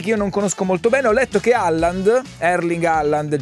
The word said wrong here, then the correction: Haaland: giocatore famosissimo. – Holland, comunque. – Holland si pronuncia?